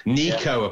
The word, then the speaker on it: Neco: Yeah, Nico, apparently it's pronounced. He's my third Forest player.